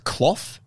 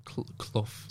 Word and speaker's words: Clough: So am I saying it's Clough? – Clough. – So Clough. – Clough. Yeah, that's right. Yeah, Clough.